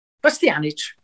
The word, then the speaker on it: Bastianich: Bastianich.